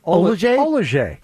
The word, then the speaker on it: Oil of Olay: Olajay? – Well, initially he's been putting Olajay in.